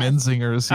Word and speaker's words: Menzingers: Menzingers. Yeah. – Oh, what? Okay, I'm going to scratch that. Hold on, take it back. Eric, edit it. – Menzingers sounds like a birth control pill. – Menzingers.